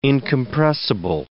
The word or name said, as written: incompressible